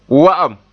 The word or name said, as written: wa'am